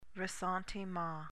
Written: ressentiment

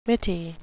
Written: mitty

